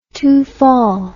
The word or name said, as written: tofall